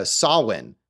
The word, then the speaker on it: Samhain: It's weird that he names the character Sam because that's how it's spelled, but it's pronounced Samhain, which is how she pronounces it in the movie.